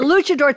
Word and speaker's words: luchador: Am I saying that right, Noble? Is it luchador? – Yes, ma'am.